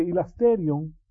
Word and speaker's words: hilasterion: Sabemos que hilasterion, o se pronuncia hilasterion en español, es el lugar de propiciación.